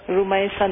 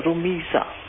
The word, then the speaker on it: Rumiza: If you pronounce it Rumaitha. – Rumaitha, no, Rumiza. R-U-M-I-Z-A.